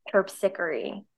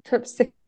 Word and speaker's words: Terpsichore: Terpsichore. – Terpsichore.